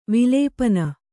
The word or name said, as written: ವಿಲಪನ